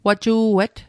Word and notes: waju'et: Mi'gmaq

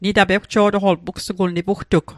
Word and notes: ewjo'toqol: Mi'gmaq

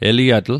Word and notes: eliatl: Mi'gmaq

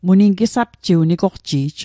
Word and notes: nigoqji'j: Mi'gmaq

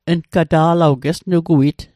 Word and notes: uggata'lal: Mi'gmaq

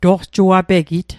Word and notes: toqjuapegit: Mi'gmaq

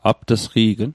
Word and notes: apt'sqi'gn: apt'sqi'kn

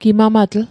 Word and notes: gimamatl: Mi'gmaq

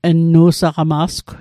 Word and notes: nnu saqama'sgw: Mi'gmaq

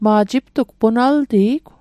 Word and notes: punaltijig: Mi'gmaq

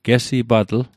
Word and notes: gesipatl: Mi'gmaq

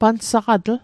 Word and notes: pansaqatl: Mi'gmaq

